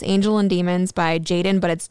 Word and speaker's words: JXDN: It's JXDN, but it's pronounced Jaden.